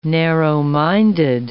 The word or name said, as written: narrow-minded